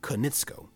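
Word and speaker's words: Konietzko: Vid, that actually did help, and I did actually watch the video, and his last name is pronounced Konitsko,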